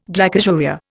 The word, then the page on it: glycosuria: Indian